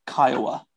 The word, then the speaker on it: Kiowa: Kiowa.